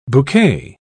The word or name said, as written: bouquet